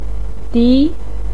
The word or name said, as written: di2